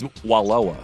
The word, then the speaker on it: Wallowa: No, it's not Walla Walla and it's not Aloha, but I think it's Walloa. Okay. W-A-L-O-W-A.